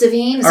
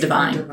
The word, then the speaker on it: Davines: Is it pronounced Daveen? – Or maybe it's Divine. – I don't know how. I think it's Davines.